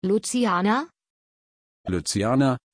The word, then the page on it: luciana: German